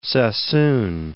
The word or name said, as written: Sassoon